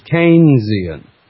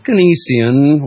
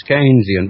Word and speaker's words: Keynesian: If you take a name like Keynes and you add an I-A-N to the end of it, you would pronounce it Keynesian, not Keynesian or some other derivation, some kind of other perversion of our language. No, it's Keynesian.